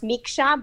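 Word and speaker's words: Micsa: So on Instagram, they just need to look me by my name, Carmen Miksa, and it's M-I-C-S-A. And it's actually pronounced Miksa, but everybody says it Miksa, you know. And Facebook is the same. It's Carmen Miksa.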